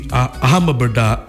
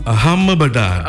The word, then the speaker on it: Ahmedabad: going to be held in Ahmedabad. Ahmedabad. – I want to know where this place is. Come on. – Ahmedabad.